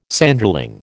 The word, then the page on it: Sanderling: American English